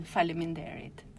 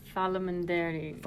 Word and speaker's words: Faleminderit: Faleminderit. – Faleminderit.